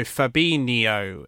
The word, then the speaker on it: Fabinho: Because in Portuguese, the NH is, so Fabinho is the way. So if you think in Spanish, the N with a little squiggle at the top, that's to pronounce that ny sound, not rather than the hard N.